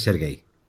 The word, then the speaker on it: Sergei: Lo que sí está claro es que es Sergei.